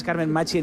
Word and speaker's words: Carmen Machi: Carmen Machi, que es Carmen Machi en italiano.